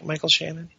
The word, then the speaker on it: Michael Shannon: Not the bad guy, but Michael Shannon is the am I saying his name right? Michael Shannon? – Yeah.